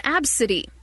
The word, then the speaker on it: Abcde: This is Absidy, but her name is spelled ABCDE.